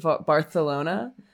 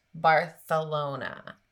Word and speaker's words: Barcelona: So I told you about this because I myself have visited Barcelona, which is actually how you pronounce it. And I'm very well versed in Spanish. – So Liz, you know this, but there, and it, the, you know, this, And there were some beliefs. There were some beliefs that King Ferdinand was born with a lisp, and that's why it is pronounced Barthelona.